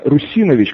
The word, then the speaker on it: Русинович: Это ударение на самом деле правильное — Русинович, как его, его называют, да.